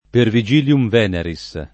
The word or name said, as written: Pervigilium Veneris